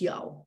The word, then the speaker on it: Yeoh: And her surname is pronounced Yeoh.